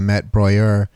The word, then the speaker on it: Met Breuer: that's open now at the Met Breuer. That's how you're supposed to pronounce it, apparently.